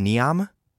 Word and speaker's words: NEOM: is it pronounced NEOM? N-E-O-M? – Yes.